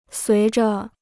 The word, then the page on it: 随着: suí zhe